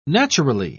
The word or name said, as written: naturally